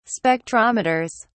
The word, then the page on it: spectrometers: British English